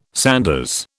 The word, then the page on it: sanders: British English